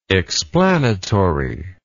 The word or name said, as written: explanatively